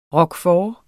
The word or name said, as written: roquefort